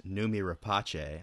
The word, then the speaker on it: Noomi Rapace: In Prometheus, there is another scene where one of the crew members, played by Swedish actress Noomi Rapace, I think that's how you say her name. – Well done.